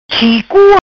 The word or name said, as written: Chicu